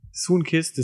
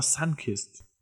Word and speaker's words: Sunkist: Oder man hat immer gesagt Soonkist, aber wahrscheinlich heißt es einfach Sunkist. – Soonkist. Ja, nee, das schreibt man aber auch S. Das schreibt man nicht Sun, äh, das schreibt man S-U-N-K-I-S-T.